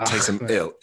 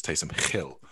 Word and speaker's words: Taysom Hill: I pronounce the hard H. It's not Taysom Ill. It's not Taysom Ill, is it? It's Taysom Hill.